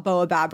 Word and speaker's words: baobab: And these are ingredients that, like, you know, we have heard about baobab, right? It's actually used a lot in hair care. – Oh, thank you for pronouncing that correctly. – Yeah, baobab actually...